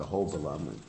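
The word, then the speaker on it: Ahobilam: They pronounce it Hobalam. Actually, the right Sanskrit pronunciation is Hobalam with a V instead of a B.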